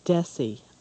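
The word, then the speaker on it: Desy: The Princess Who Stood on Her Own Two Feet by Jean Desi. I believe it's pronounced, it's D-E-S-Y.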